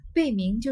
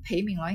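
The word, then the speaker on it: 焙茗: 原来啊。这个嗯，孩子啊，这个女孩子啊，应该就是这个贝明就是我我刚刚读成裴明了，应该是贝，应该烘焙不是烘焙吧？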